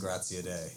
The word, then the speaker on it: Graziadei: That's all right, Jason Graziadei. It's a tough Italian name. – Graziadei. – Yes, exactly. – Italian. I like that. – My grandma was saying it. That's how she'd want me to pronounce it.